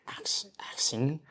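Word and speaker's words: Axshin: Working in childcare, I have some...come across a few, but a weirdest name was A-X-S-H-I-N. A-X-S-H-I-N. A-X-S-H-I-N. – Action? – It's pronounced action.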